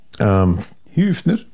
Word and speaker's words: Höfner: Höfner? – Yes.